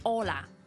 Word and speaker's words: Hola: 咁 Hola 咧，佢系串 H O L A 嘅，但喺西班牙文咧 H 咧系永远都系 silent，即系唔发音嘅。系啦，咁所以咧，我哋见到人无论任何人啦，男女老幼，同佢打招呼 Hola，或者咧。熱情啲嘅可以講多幾次，Hola Hola Hola Hola，哦，原來就係咁。